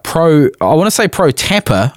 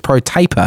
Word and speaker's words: Pro Taper: And I broke the clutch perch, which I've since replaced with a pro tapper. I've always pronounced it pro tapper, but apparently it's pro taper. – Yeah, I've always heard it as pro taper.